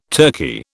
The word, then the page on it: Turki: British English